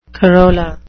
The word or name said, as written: corolla